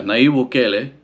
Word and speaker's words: Nayib Bukele: But this guy's name, Nayib, how would you say that? Yeah, Nayib Bukele.